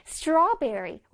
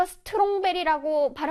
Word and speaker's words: strawberry: strawberry, strawberry. 우리 친구들 이거 strongberry라고 발음하는 친구들 있지? 그치? 아니에요. strongberry 아니야. 힘센berry 아니야.